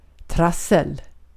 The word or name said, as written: trassel